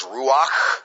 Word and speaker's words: ruach: As a matter of fact, you can't really pronounce them properly without breathing. It has a sort of breathy sound to it. The Hebrew word for spirit is ruach.